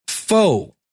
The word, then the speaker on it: faux: Faux. Oh, faux apparently is how you pronounce that.